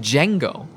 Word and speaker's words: Django: You put the D on the beginning. I think it's a soft J. – Django. – I think it's just Django.